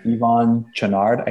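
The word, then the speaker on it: Yvon Chouinard: Yvonne Chouinard, I think is how you pronounce it.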